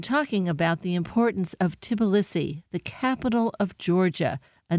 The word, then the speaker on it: Tbilisi: And we should say that's spelled T-B-I-L-I-S-I? – Yes, Tbilisi.